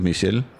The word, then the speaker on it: Michèle: Altså den, den, udtale, den gør jo så, at man udtaler edet som et æ, for ellers ville det være Michelle, hvis ikke der var noget over.